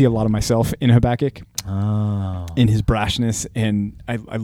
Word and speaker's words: Habakkuk: Is I think that's probably more because that isn't that how Hebrew works? It emphasizes the last syllable Habakkuk.